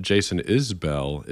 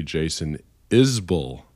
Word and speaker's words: Isbell: By the way, it's not Jason Isbell. It's actually Jason Isbell, I learned.